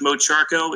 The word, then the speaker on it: Mocharko: Yeah, you can find me online by searching for my name, George Mocharko. It's spelled M-O-C-H-A-R-K-O.